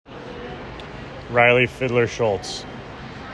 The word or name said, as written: Riley Fiddler-Schultz